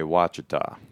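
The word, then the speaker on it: Ouachita: The show sheet says Wichita. – There's a pronouncer. – It does say Wichita. – If you're from southern Oklahoma or Arkansas, you'd say Wachita.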